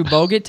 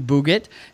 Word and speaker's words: Bogut: Andrew Bogut to Andrew Bugut.